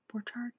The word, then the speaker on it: Borchardt: Is that how you pronounce that? Borchardt? – Yeah.